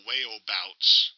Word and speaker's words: Waeubauts: His name is actually pronounced Dale Wayobouts. According to my first script, that's spelled Waeubauts.